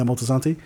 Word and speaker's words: Moltisanti: How do you say it? Moltisanti. Yeah, Moltisanti. Because, you know, this character's been talked about a lot mm-hmm.